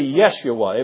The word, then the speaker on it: Yeshua: The Jews would pronounce it today Yeshua.